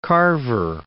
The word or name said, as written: Carver